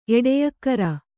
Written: ಎಡೆಯಕ್ಕರ

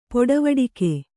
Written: ಪಡಿವಟ್ಟಿಗೆ

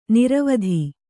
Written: ನಿರ್ವಾತ

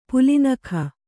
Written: ಪುಲಿನಖ

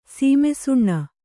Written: ಶ್ಮಶಾನ

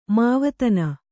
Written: ಮಾವತನ

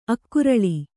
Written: ಅಕರಾಳ

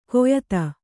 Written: ಕೆಯ್ದು

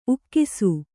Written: ಉಗಿಸು

